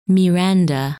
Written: Miranda

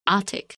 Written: Atik

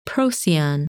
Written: Procyon

